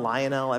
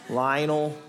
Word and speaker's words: Lionel: Cape Verde is like this small little island country in Africa, and the population is small, and they're playing Argentina with Lionel? Everyone pronounces it different. Lionel. I'm going to call him Lionel.